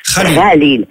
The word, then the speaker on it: Khalil: Comment vous le prononcez exactement? C'est Khalil Gibran? – Khalil. – D'accord. – Le c'est en arabe.